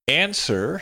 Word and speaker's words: answer: Answer has a long A, a silent W, and then the er sound at the end.